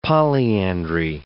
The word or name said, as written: polyandry